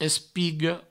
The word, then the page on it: espiga: provençal; espigo